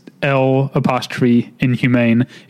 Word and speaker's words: L'Inhumaine: which is a 1924 French film called La Inhumaine. – Okay. – I'm not sure if I'm saying that right. It's L'Inhumaine. It means the inhuman woman.